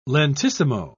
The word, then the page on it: lentissimo: British English